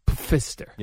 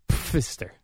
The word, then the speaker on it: Pfister: And Pfister, by the way, is spelled PF. It's one of those. – Mm-hmm, like Pfizer. – Pfister. – Yes. How do you say that? – Pfister.